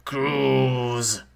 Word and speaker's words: cruise: And secondly, the pronunciation is cruise. Cruise. Zzz, soundness.